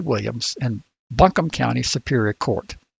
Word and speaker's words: Buncombe: I believe it's Buncombe. I've been through there, and I think I've heard it pronounced that way. But again, North Carolinians, we got thousands of them, so they can tell us. But I think it's Buncombe.